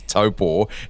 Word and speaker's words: Taupo: How did this deal to go to Aussies say Taupo? The correct pronunciation is Taupo.